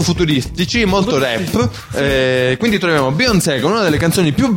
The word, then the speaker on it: Beyoncé: Beyoncé. – Beyoncé.